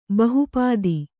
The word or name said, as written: ಬಹುಪಾದಿ